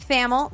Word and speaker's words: Thamel: Pete Thamel, rhymes with camel, and our friend Pat Forty.